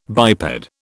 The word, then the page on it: biped: British English